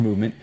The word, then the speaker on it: MVMT: Guys, if you want to look it up, it's called Movement, but it's MVMT. It's pronounced Movement.